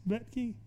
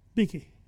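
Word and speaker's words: Bethge: How do you say Betke? Is it Betke? – Betke.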